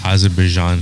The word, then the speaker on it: Azerbaijan: Yeah, Azerbaijan.